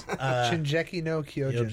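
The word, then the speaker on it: Shingeki no Kyojin: No, it's Shinjeki no Kyojin.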